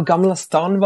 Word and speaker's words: Gamla Stan: Wie spricht man es aus? Gamla Stan? – Genau, Gamla Stan waren wir, ja, da ein bisschen shoppen.